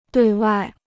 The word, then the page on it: 对外: duì wài